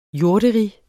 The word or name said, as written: jorderige